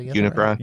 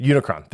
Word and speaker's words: Unicron: And then, you know, there's hints of Galvatron and Omnicron. Oh, wait, no, it's not. How do you say it? Is it Omnicron? – Unicron. – Unicron. Thank you.